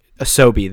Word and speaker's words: Asobi: So what they're doing is they're closing down they're closing down these offshoot studios to focus on Astro's Playroom, which is the Astromi one. Did I say that right? Asobi, there it is.